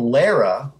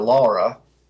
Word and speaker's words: Lara: One thing I noticed that really kind of jumped out at me was Superman's mother is called Lara, or Laura, however you want to pronounce it. I've heard it pronounced both ways.